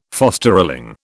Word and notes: fosterling: British English

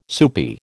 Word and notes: soupy: American English

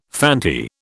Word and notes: Fanti: British English

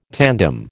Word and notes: tandem: American English

